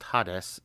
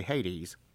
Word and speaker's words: Hades: Typically, it was what we call Hades, or I've also heard it pronounced Hades, and that probably makes sense from a Greek point of view. James White says Hades. Others, especially Southerners like myself, say Hades, because that's how we were taught growing up.